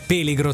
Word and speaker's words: peligro: Assolutamente, la parola spagnola si pronuncia peligro e significa pericolo, anche se...